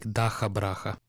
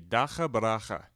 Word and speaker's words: Dakhabrakha: It's called Daka Braka, and uh, could I get a pronunciation on that? – Yeah, yeah, it's like Dakhabrakha. – Okay, Dakhabrakha.